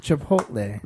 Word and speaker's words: Chipotle: I pronounce it incorrectly every time. I realize that. Chipotle? Chipotle? – He's forgetting half the word. – Chipotle. – There you go.